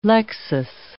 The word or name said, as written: lexis